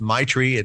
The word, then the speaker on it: Meichtry: My wife's grandfather, Stefan Meichtry, and that's how the vineyard is pronounced, Meichtry. It's like I have a tree in my front yard, and that's Meichtry.